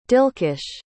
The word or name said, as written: dilkhush